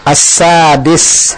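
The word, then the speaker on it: As-Sadis (السادس): As-Sani. – As-Sadis. As-Sadis. Pakai dal. As-Sadis. – As-Sadis.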